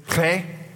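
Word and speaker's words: Chet: This week we're in, and the right pronunciation we're not going to do. It's Chay, because you might spit on your neighbor.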